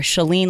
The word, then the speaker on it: Shalene: I would say Shalene like shut the door, or Shalene like Celine Dion, except I don't have her voice.